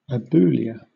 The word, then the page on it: aboulia: British English